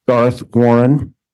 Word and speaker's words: Garth Warren: Garth Warren, GARTHWARREN.